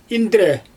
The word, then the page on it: indre: Xyzyl